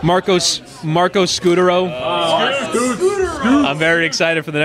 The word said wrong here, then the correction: Scutaro: I believe it's Scutaro. – Is it? – Oh, wow. – Yeah, from the pronunciation of MVP baseball.